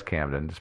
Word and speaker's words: Chipping Campden: It begins on the 16th of August in the year 1660, when William Harrison set off on foot from the village of Chipping Campden. I think that's Camden. What do you guys think? – I think it's Camden. – Mm-hmm. Yeah, it's Camden. It's spelled Campden, but I'm sure it's Camden.